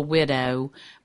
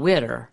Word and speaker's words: widow: And it just doesn't sound right to call it Pretty Little Widow, because it's always sung Pretty Little Widder, because it has to rhyme with Go and Get Her.